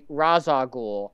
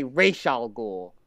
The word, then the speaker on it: Ra's al Ghul: I don't know if anybody else will find I also would like to point out that, yes, in adaptation, there are conflicting pronunciations of the name, because mm-hmm. the Nolan films say Ra's al Ghul. But the animated series, and I believe possibly the Arkham games as well, I can't remember, say Ra's al Ghul. – Ra's al Ghul? – And no one can decide which one is the canon version, is the canon pronunciation.